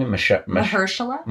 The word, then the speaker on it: Mahershala: Mahershala? – Mahershala.